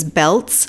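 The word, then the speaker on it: Beltz: My last name is Belts, B as in boy, E-L-T-Z.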